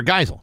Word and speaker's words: Geisel: But it is pronounced Geisel, right? – Theodore Geisel. Yeah, not Geisel.